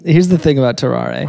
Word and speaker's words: Tarrare: Tarrare.